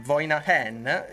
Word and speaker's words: Voinahen: Ma come vi abbiamo preannunciato nell'anteprima, abbiamo qui con noi in diretta Ivo dei Voinahen. Io spero di averlo pronunciato bene. Ho chiesto proprio se la pronuncia era questa, mi è stata confermata che era questa. Ivo, è corretto? – Sì, sì, correttissimo.